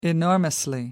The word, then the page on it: enormously: American English